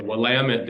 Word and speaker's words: Willamette: Yeah, so I learned this when I moved to Portland, but I got corrected several times, but it's the Willamette, damn it.